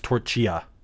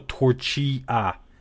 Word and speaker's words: Torchia: It's actually pronounced Torchia. – Are you just... You're just, like, playing the field with me, ain't you? – No. I wouldn't do that to you. – You told me before that it was Torsha. – No, Torchia. It's three syllables.